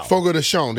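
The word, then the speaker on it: Chão: Ike Dog on Twitter just said, it's like the Fogo people when you mispronounce Chow. What? Fogo de Chow. – Fogo de Sean, they said. That's what it is. – De Sean? – Yeah, that's what I've heard. But I'm like, that's C-H-O. – C-H-A-O. – C-H-A-O.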